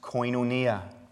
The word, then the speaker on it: koinonia: The word behind partnership in Greek is a word pronounced koinonia.